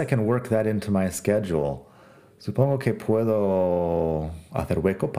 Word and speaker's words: schedule: Schedule es horario y empieza con S, C, H, pero la pronunciación sk, schedule, es una de las palabras un poco más complicadas de pronunciar en inglés.